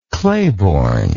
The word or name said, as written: claiborne